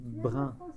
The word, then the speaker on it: brun: How do you say brown again? – Brun.